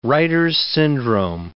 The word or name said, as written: Reiter's syndrome